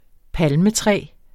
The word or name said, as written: palmetræ